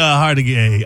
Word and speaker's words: Hargitay: Mariska Hargitay. Hargitay. – Is it Hargitay or Hargitay? – H-A-R-G-I-T-A-Y. – I'll be damned. I've been saying Hargitay all this time.